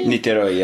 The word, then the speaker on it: Niterói: So, to get these started, you were born in Brazil, and I don't want to butcher the name, but Niterói? – Niterói, yeah.